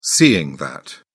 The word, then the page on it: seeing that: British English